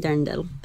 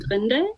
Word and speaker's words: Dirndl: It's Ich will ein Dirndl. – Am I saying it right? – You're pretty close, actually. I think you're trying to say Ich will ein Dirndl.